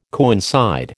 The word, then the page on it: coincide: American English